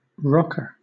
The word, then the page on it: rocker: British English